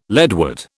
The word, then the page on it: leadwort: British English